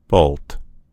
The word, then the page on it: bolt: American English